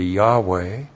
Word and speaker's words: Yahweh: Now here, the Tetragrammaton, Yahweh, if you actually pronounce it in the Hebrew, be Yahweh.